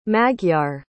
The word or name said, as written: magyar